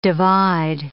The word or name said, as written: divide